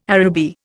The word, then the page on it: Araby: Indian